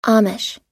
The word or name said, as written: amish